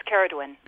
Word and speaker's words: Ceiridwen: It's Ceirdwen.